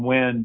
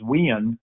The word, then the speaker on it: Wynn: I do tell people, though, anytime you go back to Bristol or back to my hometown, it's where my name, Wynn, becomes two syllables and it becomes Wien, where it gets drug out a little bit.